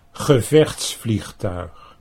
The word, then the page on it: gevechtsvliegtuig: Dutch